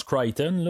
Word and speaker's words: Crichton: C'est dans le fond, c'est marqué comme Crichton son nom, mais ça se prononce Crichton.